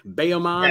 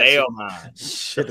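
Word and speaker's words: Bayamón: Bayamon, yes, is how that was Bayamon. – Pronounced. – Yes. Bayamon.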